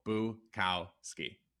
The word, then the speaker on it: Bukowski: So Boo, cow, ski.